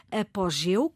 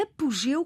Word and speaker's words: apogeu: E surpreendi-me porque os nossos dicionários de referência aceitam as duas pronúncias apogeu com o aberto hum. e apogeu com o Portanto, a resposta é que as duas formas de pronúncia são aceites.